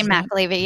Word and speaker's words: McAlevey: Jane McAlevey, yeah.